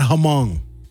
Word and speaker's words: Hmong: And it's pronounced Hmong, not Hmong. Actually, it's Hmong. Hmong, you got to say it right.